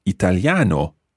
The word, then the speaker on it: italiano: I would say sono italiano with an O sound at the end as opposed to an A sound.